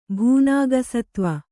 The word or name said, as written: ಭೂನಾಗಸತ್ವ